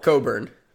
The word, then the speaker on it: Cockburn: Coburn. – Coffee Cockburn. – It's spelled Cockburn. It's pronounced Coburn.